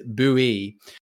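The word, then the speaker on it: Buoy: Correct pronunciation is Bowie.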